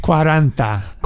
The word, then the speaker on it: Cuarenta: Cuenta? You're two-thirds of the way there. Kind of. Keep trying. – What is it? – I know. Cuarenta. You got it.